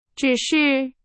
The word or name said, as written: 只是